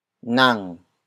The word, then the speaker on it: nang: Siya is spelled as S-I-Y-A. It means he or she, the focus of the sentence. The next word is nang. Nang. Nang. Nang is spelled as N-A-N-G.